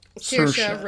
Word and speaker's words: Saoirse: Saoirse Ronan. – Thank you. From Little Women. – Rhymes with inertia.